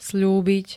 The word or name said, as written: sľúbiť